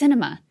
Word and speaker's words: Sinema: In it, Dowd writes, the Arizona senator's name is pronounced Sinema,